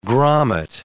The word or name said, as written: grommet